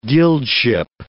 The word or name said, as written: guildship